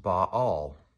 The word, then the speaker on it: Baal: You know, in the Bible, there's this false heathen god who is worshipped, who is called Baal, B-A-apostrophe-A-L, could be pronounced Baal.